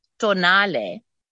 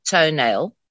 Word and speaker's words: Tonale: But the new Alfa Tonale, which is the very right pronunciation, not toenail, as some people say.